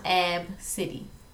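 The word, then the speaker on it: Abcde: So the mama, you know, felt a little bit about that, but the girl name is the first 5 letters of the alphabet. – She, but it's pronounced Ab City.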